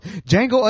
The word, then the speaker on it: Django: I'm curious to see what you think of 2013's, I almost pronounced it the way it's spelled, Django Unchained.